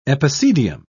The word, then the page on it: epicedium: British English